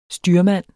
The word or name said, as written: STYRMANDEN